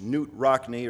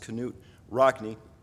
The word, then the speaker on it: Knute Rockne: You may have heard of the coach Newt Rockne, or sometimes his name pronounced Knute Rockne.